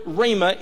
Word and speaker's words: Rhema: The word sword here comes from an interesting Greek word, R-H-E-M-A, and it's pronounced Rhema.